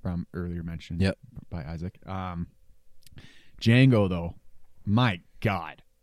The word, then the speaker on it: Django: Django. Can you spell it? – D-J-A-N-G-O. The Dia Silent.